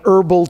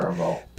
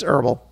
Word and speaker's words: herbal: Some people pronounce the letter H in that word. They should know that the H doesn't feel bad. – Herbal. – People don't. Don't think that the H feels bad because you didn't pronounce it. It's herbal. That's all there is to it. I'm not going herbal.